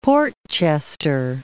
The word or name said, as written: Port Chester